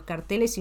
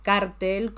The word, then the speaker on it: cártel: La palabra aguda, cartel, es la que se usa para los carteles informativos o anuncios publicitarios. En cambio, cártel, como organización económica o delictiva, se puede escribir con acento en la A, cártel, o sin acento, cartel, como recomienda la Real Academia.